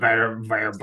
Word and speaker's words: verboten: If you're using a German word, you should use the German pronunciation. – Verboten. – So it's an F sound.